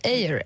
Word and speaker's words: Eyre: Eh vad säger man, ett litet passus här för, för ut, hur man uttalar men John Eyre, säger man så? Eyre? – Eyre, ja, tycker jag. Eyre.